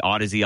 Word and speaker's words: Audeze: And then the planar magnetic headphones, I'm really big into the Audizy LCD 3, which is outstanding. Audizy, it looks like, some people pronounce it Audiz, A-U-D-E-Z-E.